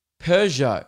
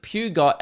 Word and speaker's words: Peugeot: I didn't even know how how to pronounce Peugeot or Peugeot. I pronounced it Pugot, and I think that's why.